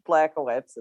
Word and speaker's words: Blackowitz: Um, my last name is pronounced Blackowitz. It's it's not hard, guys.